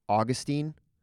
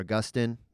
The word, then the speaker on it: Augustine: I mean, Manichaeanism, I believe, was something that Augustine or Augustine, depending on how you want to pronounce his name, was involved in.